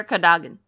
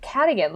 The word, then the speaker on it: Cadogan: That was, like, reading it, I always said Cadogan, like cardigan.